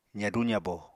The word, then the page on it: zadu-zabo: apatani